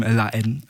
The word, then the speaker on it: La Haine: Den französischen Film La Haine empfohlen. Mhm. Habe ich richtig ausgesprochen? Ja. Das H ordentlich weggelassen, so wie es in der französischen Sprache.